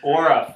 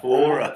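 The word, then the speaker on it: Oura: How do you say it? – It's Aura. – Aura. – It's like your aura.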